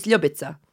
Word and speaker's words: Ljubica: I mean, my full name is Ljubica.